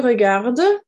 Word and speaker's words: regardes: Okay, tu regardes, it's pronounced exactly the same, but we have an S on the end.